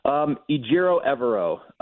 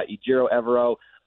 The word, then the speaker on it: Ejiro Evero: Ejiro Evero is sort of how we've come to know that his name is pronounced. But yeah, it took us all out here just a little bit to get that right.